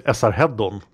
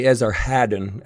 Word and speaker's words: Esarhaddon: It's of course none of us will use the right pronunciation because the true name is something very different. But in Swedish, we say Esarhaddon. – I think you said it correctly.